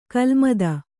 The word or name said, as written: ಖಲಮತ